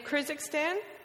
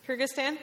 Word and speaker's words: Kyrgyzstan: Yeah, in Kyrgyzstan, and I'm not pronouncing it correctly. Kyrgyzstan, thank you.